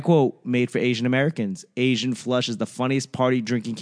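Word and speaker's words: AZN Flush: They sent me a free game and it's called Asian Flush. And, you know, it's spelled A-Z-N flush.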